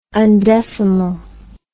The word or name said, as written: undecimal